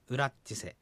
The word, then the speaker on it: ウラッチセ: ウラッチセなんですけども、笹吹きの家ってことなんですけども、ゆっくり言えば、うらし、ちせなんですけども、普通の速さで発音するとこういうふうに発音されます。うらっちせっていうふうに発音されます。